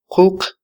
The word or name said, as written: quq